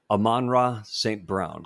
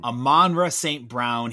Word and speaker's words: Amon-Ra St. Brown: Amon-Ra St. Brown. – Amon-Ra St. Brown.